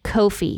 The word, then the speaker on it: Ko-fi: I just found out that it's pronounced Ko-fi, and I'm very sad about that.